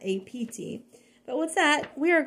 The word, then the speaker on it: Abt: That's A as in apple, B as in boy, T as in Thomas, but pronounced apt.